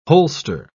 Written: holster

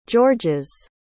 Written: george's

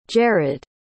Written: jarad

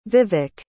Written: vivek

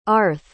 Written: arthw

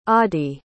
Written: audy

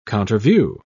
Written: counterview

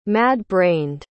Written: mad-brained

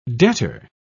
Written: debtor

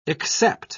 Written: except

